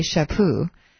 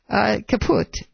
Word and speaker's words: Chaput: I'm told it's pronounced. I would say Chaput from my French, but that might not be correct. Or Pepe Le Pew, or Caput.